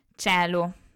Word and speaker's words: caelum: I dittonghi AE e OE si pronunciano E, come abbiamo detto anche nelle precedenti lezioni, quindi se troviamo la scritta caelum leggiamo cielo, come simile all'italiano. Se troviamo la scritta coena leggiamo cena ok?